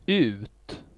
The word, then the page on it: ut: /ʉːt/